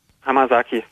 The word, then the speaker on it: Hamasaki: Murakami Haruki, aber egal, wir hören jetzt Ayumi Hamasaki oder so ähnlich. Wie spricht man das richtig aus? – Hamasaki.